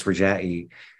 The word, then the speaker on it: Rajai: Yeah, so maybe I'll mention that my name is Rajai, and it has a meaning, and the meaning of my name in Arabic, it's actually pronounced Rajai.